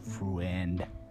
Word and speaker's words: Freund: If not, it came from the word friend. That's pronounced F-R-E-U-N-D, friend, mean to love.